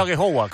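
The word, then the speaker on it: Aghehowa: He dicho Agyehogwa, ¿cómo se pronuncia? – Sí, bien, la verdad que...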